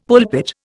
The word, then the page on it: pulpit: Indian